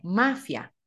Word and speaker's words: mafia: Just remember one F. It's not spelled with two F, but one. – So don't make that F a longer F sound. It's just supposed to be mafia.